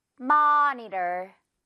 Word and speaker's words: monitor: 모니터 아니고요. monitor. 어 발음 길게 똑같습니다.